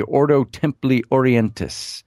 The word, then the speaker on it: Ordo Templi Orientis: He's in the and I don't even know how to pronounce some of these things, but if I'm pronouncing it right, the Ordo Templi Orientis. – There you go. You got it.